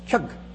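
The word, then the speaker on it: Utqiaġvik: If I were to try to write this out for you phonetically in English, I would not spell it capital K, capital Y, capital U, capital G as kyug, and not quite capital K-E-Y, capital U-G, ki-ug. It's almost like capital K, small y, U-G. It's kyug.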